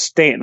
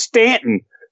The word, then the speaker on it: Staunton: Once I learned that Staunton is pronounced Stanton, I shut up and just walked. I was like, it's got a U in it. Nay, it's pronounced Stanton.